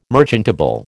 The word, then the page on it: merchantable: American English